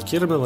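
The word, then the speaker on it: Kilmer: Это игра Вэл Килмера, разде... Килмера здесь халтура. Вот я даже неправильно произношу его фамилию, зло. Криерман. Килмер. Вот.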